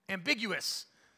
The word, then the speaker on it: ambiguous: This is not ambiguous. Am- ambiguous. I knew I threw in an extra syllable in there somewhere. I was like, That didn't sound right.